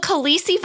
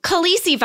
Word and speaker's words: Khaleesi: It's called Khaleesi virus.